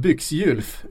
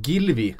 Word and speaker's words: Gylfi: Gylfi Sigurdsson, som vi brukar säga då, uttalas inte som Byxgylf utan som Gilvi.